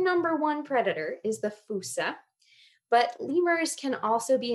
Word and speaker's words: fossa: called the fusa, and that's spelled F-O-S-S-A if you want to look it up.